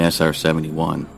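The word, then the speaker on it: SR-71: You're talking about the RS 71. – You mean SR 71? – Only because Johnson mispronounced it three times on public television.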